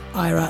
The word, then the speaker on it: Ira: His name was IRA Kaplan. – It's Ira. It's definitely Ira. – Not pronounced IRA, then? – No.